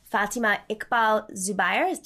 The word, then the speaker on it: Iqbal-Zubair: And if she hadn't been invited to that rally and given a platform, I would have not known about Fatima Iqbal-Zubair.